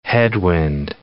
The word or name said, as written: headwind